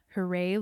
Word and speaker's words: Hooray: You can follow me on Instagram and other social media things at Hooray Lorraine, which I apologize. Lorraine is my middle name, so it's spelled L-O-R-R-A-I-N-E, mm-hmm. – and Hooray is H-O-O-R-A-Y.